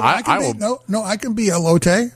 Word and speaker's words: elote: So we are having a food fight tonight with, I believe the pronunciation is elote.